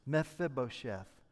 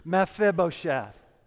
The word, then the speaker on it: Mephibosheth: Now, if you're going to walk away with something from this sermon, I want you to know how to pronounce that word. Okay? So say that with me on three. One, two, three. Mephibosheth. One more time. One, two, three. Mephibosheth.